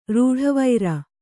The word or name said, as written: ರೂಢವೈರ